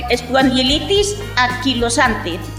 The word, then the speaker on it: espondilitis anquilosante: espondilitis anquilosante.